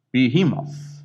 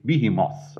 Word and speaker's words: Behemoth: And Job talks about the Leviathan, and he talks about Behemoth, or as I say, Behemoth. And I had to check that out in the pronunciation dictionary, and both pronunciations apparently are correct.